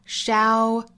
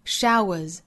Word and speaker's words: shower: 在单词内部两个元音相遇会由前面一个元音的相似口型带出这个小小的 w 的发音 shower, shower, shower, shower. 和 flower, flower, flower, flower 这两个词后面加 s 都读浊辅音的 showers 和 flowers 清清浊浊的规律。